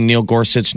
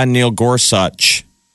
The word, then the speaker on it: Gorsuch: And he goes, ah, Neil Gorsuch. – Oh, everyone's like, so we've been mispronouncing his name.